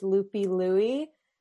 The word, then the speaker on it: Loopy Louie: I play Scrabble Go, and my name on Scrabble Go is Loopy Louie, and that is spelled, let me just check.